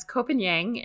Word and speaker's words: Koh Phangan: It is K-O-H is the first word, and then P-H-A-N-G-A-N. So it's spelled a little differently than it sounds.